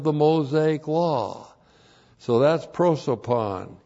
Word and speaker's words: prosopon: And then that's N is N plus the dative of prosopon. P-R-O-S, long O, P-O-N, prosopon.